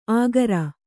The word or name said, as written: ಆಗರ